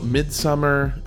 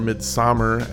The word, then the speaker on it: Midsommar: And we talk about Midsummer, or Midsommar, as I learned that it's pronounced, and its ties to the actual pagan practices that people like Katie grew up with, and it's really interesting.